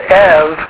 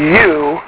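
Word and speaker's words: ev (ευ): The epsilon, ypsilon, diphthong is pronounced ev, as in evangelistic, which is where we get the English pronunciation of it. Okay? Whereas in the Erasmian, it's u, which is where we get, because the U is a prefix meaning good.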